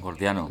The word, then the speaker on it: Gordiano: lleva al nudo Jordiano, ¿lo he dicho bien? Jordiano. – Gordiano. Sí, Gordiano, eso.